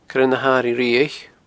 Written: coirenahairighriabhaich

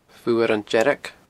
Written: Fuaran Dearg

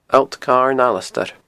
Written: Allt Carn Alasdair

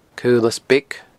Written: Caolas Beag

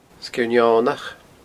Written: sgeirneonach

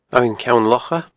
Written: Abhainn Ceann Locha

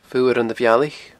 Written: Fuaran a Bhealaich

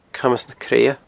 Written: Camas na Creadha

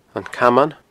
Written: Caman

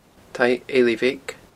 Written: taigh eilidh bheag

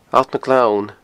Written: Allt nan Gleann

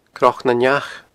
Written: cnocnaneach

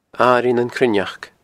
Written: Airigh nan Cruithneachd

